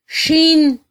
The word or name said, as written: xin